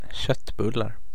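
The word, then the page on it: köttbullar: Swedish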